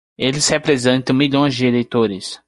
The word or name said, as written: representam